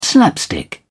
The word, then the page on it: slapstick: British English